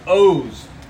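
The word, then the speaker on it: oz (עֹז): The word for strength that he uses here, this is a Hebrew word, and it's pronounced oz.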